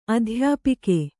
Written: ಅಧ್ಯಾಪಿಕೆ

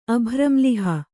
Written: ಅಭ್ರಂಲಿಹ

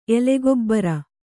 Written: ಎಲೆಗೊಬ್ಬರ

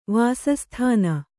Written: ವಾಸಸ್ಥಾನ

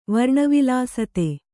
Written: ವರ್ಣವಿಲಾಸತೆ

